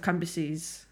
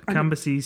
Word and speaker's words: Cambyses: And again, I've looked it up. – Sisamins. – And I think that's how you say it, on the order of Cambyses. – Oh my God. Cambyses, Sisamins.